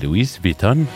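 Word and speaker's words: Louis Vuitton: هي أنا وجدت أنها تنطق بطريقتين, لويس فيتون ولوي فيتون, فبالتالي يعني أغلب مستمعينا سواء عاشة خليفة أمل كتبوا لويس فيتون, وبالتالي هي ماركة من الماركات العالمية.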